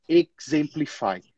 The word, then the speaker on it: exemplify: Aquele X ali, ó, tenta pronunciar ele mais destacado. Exemplify. Não exemplify, como a gente faz em português, mas exemplify.